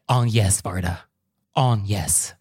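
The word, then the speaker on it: Agnès Varda: He's like, Agnes Varda. On yes. And it's like, okay, that is the correct pronunciation.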